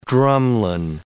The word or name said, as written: drumlin